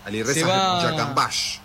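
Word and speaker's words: Alireza Jahanbakhsh: Ali Reza Yakambash. – Este era de apellido complicado, ahora me lo acordé. Número 7, claro, sí. Alireza Yakambash, así es como se pronuncia.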